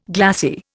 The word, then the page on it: glassie: Indian